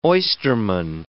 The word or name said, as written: oysterman